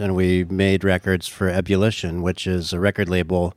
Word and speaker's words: Ebullition: On Ebullition.